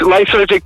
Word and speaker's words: Lysergic: Then 2013, Liz Jerky Tea Party. Did I pronounce it correctly? – Correct, Lysergic Tea Party. Lysergic Tea Party, yes, that's correct, yeah.